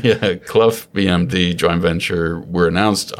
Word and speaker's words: Clough: is it Clough? C-L-O-U-G-H? – Clough. – Clough. – Clough. – Yeah.